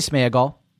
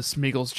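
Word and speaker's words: Sméagol: I would say Smeagol. – Okay. – I think the accent is on the E, and I would say it's Smeagol.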